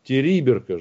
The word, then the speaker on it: Териберка: На И ударение, господа, 200 рублей. Териберка же. – Териберка. – Териберка? – Териберка, да, совершенно верно.